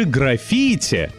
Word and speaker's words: граффити: Да это же граффити! И никакое не граффити, как бы долго не пытался вас убедить в таком ударении друг-художник. Он просто не слушает раздраженного филолога. Граффити и только граффити.